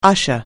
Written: usher

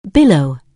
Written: billow

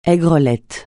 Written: aigrelet

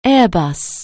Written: Airbus

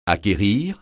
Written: acquérir

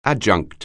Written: adjunct

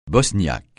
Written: bosniaque

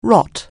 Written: rot

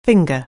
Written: finger

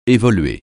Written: évoluer

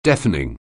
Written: deafening

